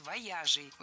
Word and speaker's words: вояжей: Выезжей – неверно. Ударение на окончание переносить не следовало. Правильный вариант – вояжей. Ударение всегда сохраняем на «я» во втором слоге. Вояжи, вояжей, вояжами.